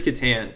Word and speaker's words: Catan: We used to call it Catan, but then I'm pretty sure it's Catan. – Yeah. – Well, maybe we have to have, because we love playing a little Catan.